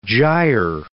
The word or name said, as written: gyre